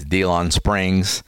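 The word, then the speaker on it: DeLeon Springs: I've gone out there to, and then you have I always say it wrong. It's DeLon Springs.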